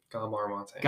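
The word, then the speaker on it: Gabor Maté: Gabor Mate. Gabor, G A B O R, and then Mate is spelt mate.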